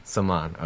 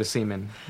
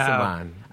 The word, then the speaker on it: Semahn: It's a very tricky one. Everyone wants to go to semen every single time. It's so weird. – I was trying to pronounce it, and then like the fourth time, I was like, is it semen? – Yeah, no. Semahn. I think everyone's mind just goes right to semen.